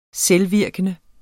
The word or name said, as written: selvvirkende